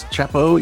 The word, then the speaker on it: Chapo: Uh, it's Chapo, easy on the A, hard on the L.